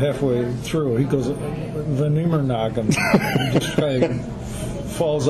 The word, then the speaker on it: Amerongen: Can I say it right? Amerongen? – Amerongen, okay. – Yeah. You said that's how I say it.